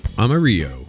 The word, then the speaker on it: Amarillo: And they say that the, I hope I'm pronouncing it right, Amarillo hop. – Amarillo. – Okay. – That is correct.